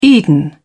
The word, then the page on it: eden: British English